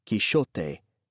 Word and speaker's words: Quixote: Quichotte, spelled Q-U-I-C-H-O-T-T-E and pronounced Quichotte in French and Quichotte in German, and C-H-I-S-C-I-O-T-T-E, pronounced Quixote in Italian, are alternative spellings slash pronunciations of the Spanish Q-U-I-X-O-T-E or Q-U-I-J-O-T-E, pronounced Quixote. Portuguese also uses a sh sound rather than a h sound for the X or J in the middle of Don Quixote's illustrious name.